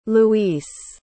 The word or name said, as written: luís